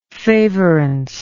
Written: favorance

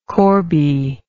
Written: corby

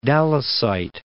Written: Dallasite

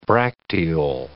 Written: bracteole

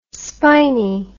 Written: spini

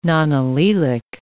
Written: nonallelic

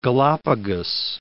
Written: Galapagos Islands

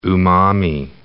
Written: umami

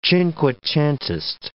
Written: cinquecentist

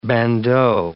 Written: bandeau

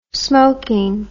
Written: smoking

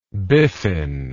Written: biffin

